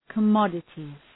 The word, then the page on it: commodities: kə’mɒdətız